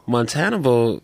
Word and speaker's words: Montanabal: Montana Bowl rhymes with Hannibal.